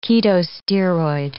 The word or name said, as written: ketosteroid